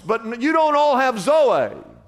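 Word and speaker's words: zoe (ζωή): It says, I've come to give you, and he uses the word zoe, Z-O-E, but it's pronounced zoe. Everybody say zoe.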